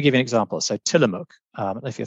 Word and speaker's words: Tillamook: It's called Tillamook. T I L L A M O O K.